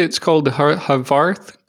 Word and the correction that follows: Horvath: clock. Is that pronunciation correct that you're mentioning? – Yeah. So I think it was Steve Horvath, but regardless of the pronunciation, that's exactly it.